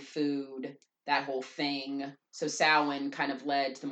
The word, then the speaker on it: Samhain: Even though it's pronounced Samhain, but it's spelled S-A-M-H-A-I-N. – Samhain.